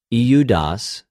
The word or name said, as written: Ἰουδας